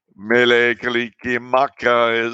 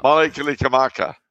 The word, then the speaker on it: Mele Kalikimaka: I can never pronounce it properly. Let me see. Mele Kalikimaka is the way. That's the one. – Yeah. – Mele Kalikimaka.